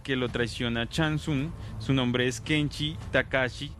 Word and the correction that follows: Takahashi: Takahashi, perdón.